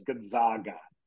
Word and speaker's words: Gonzaga: The pronunciation I'm seeing, by the way, is Gonzaga. Za. So I do think that's the emphasis. – Gonzaga. – Gonzaga. – So it's Gonzaga, but people say Zags.